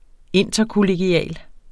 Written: interkollegial

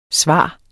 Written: svare

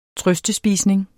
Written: trøstespisning

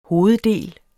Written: hoveddel

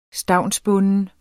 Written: stavnsbunden

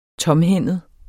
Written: tomhændet

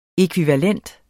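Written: ÆKVIVALENTE

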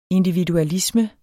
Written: individualisme

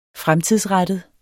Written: fremtidsrettet

